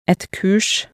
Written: et kurs

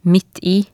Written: midt i

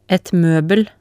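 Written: et møbel

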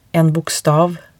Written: en bokstav

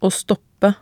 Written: å stoppe

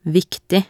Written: viktig

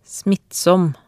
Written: smittsom

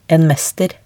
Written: en mester

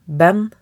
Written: Ben

